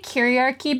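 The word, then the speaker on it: kyriarchy: You know, I was recently introduced to the term chiarchy or chiarchy. How do you say it? – I think I say chiarchy because in the Christian liturgy, you say Kyrie eleison, which is like Lord have mercy.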